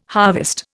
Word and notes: harvest: Indian

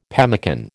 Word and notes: pemmican: American English

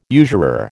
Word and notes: usurer: American English

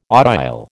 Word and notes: audile: American English

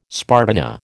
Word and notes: spartina: American English

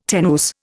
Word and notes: tenuis: Indian

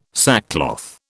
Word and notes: sackcloth: British English

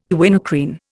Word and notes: quinacrine: Indian

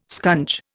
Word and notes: scunge: Indian

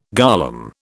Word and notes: golem: British English

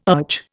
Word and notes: urge: Indian

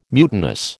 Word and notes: mutinous: American English

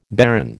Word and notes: barren: American English

